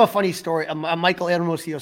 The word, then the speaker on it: Hermosillo: Michael Hermosillo. – Very good. Almost as good as Pat Hughes rolling that H.